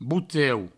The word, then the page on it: boteu: bouteu